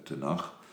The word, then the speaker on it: Tenach: En tenslotte heb je dan eh, de Ketuvim, dat is de K, de Tenach. Het wordt als een zachte K uitgesproken aan het eind van het woord uh, Tenach. Maar het klinkt dus als een G, maar dat staat eigenlijk voor een K.